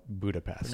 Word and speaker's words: Budapest: Or Wes Anderson just said, look, for continuity's sake, please, let's just call it Budapest. – Pronounce the T. – Yeah, I want to see the T, no shh, at the end.